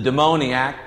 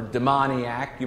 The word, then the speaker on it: demoniac: He is called the demoniac by some, or demoniac, you might pronounce it.